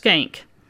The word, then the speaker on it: Shenck: And by the way, before I get too far in this, the high school is spelled S H E N C K, and it's pronounced skank.